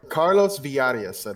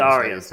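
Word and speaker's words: Carlos Villarías: Carlos Villarias, I believe. – Villarias, thank you. The double L is fucking with my brain, even though I know how it's supposed to be pronounced.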